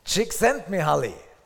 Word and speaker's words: Csikszentmihalyi: Csikszentmihalyi. Sieht aus wie ein Passwort, ist aber ein Name. Mihaly Csikszentmihalyi. Mihaly Csikszentmihalyi hat ein Buch geschrieben, das heißt Flow, das Geheimnis des Glücks.